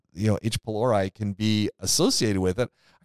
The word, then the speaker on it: H. pylori: And H. pylori. Did I pronounce that correctly? – Yeah, you pronounced that pretty good.